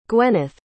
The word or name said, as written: gwenith